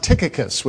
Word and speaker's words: Tychicus: which in English is usually pronounced Tychicus, which is kind of a funny sounding name.